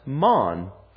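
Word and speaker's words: man (מן): The word here for "what" is an archaic Hebrew word that's pronounced "man."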